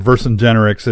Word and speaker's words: Vercingetorix: Because one of the big players on the Gauls who made life so rough on Julius Caesar was Vercingetorix. – Mm-hmm. – Or Vercingetorix, it depends on who you listen to, how it's pronounced.